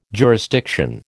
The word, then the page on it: jurisdiction: American English